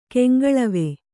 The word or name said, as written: ಕೆಂಗಳವೆ